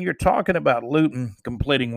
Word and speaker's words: Luton: But the Pittsburgh game, it tells you how important quarterback play is because, I mean, let's face it, Luton is somebody with the NFL Network called him, kind of rhyme with crouton, right?